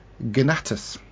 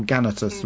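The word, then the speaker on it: Ganatus: He played a Thal called Gnatus. Remember him? Or Gannatus, depending on how you want to pronounce it.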